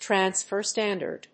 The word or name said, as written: transfer standard